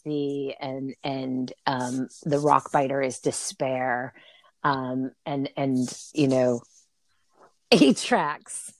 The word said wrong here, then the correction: Artax: I'm saying it wrong. It is. What's his name again? – Artax is sadness.